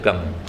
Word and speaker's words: Kang: Le nom, c'est Kang. Kang, c'est le king. Parce qu'on ne prononce pas les voyelles brèves ici.